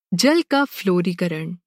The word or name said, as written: जल